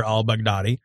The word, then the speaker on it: al-Baghdadi: It says it's from its leader, Abu Bakr al-Baghdadi.